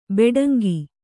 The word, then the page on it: ಭಟಾಂಗ: bhaṭānga